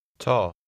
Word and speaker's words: ta: Ta. – Notice the sound of the Swedish letter A at the end of a word or when followed by a single consonant.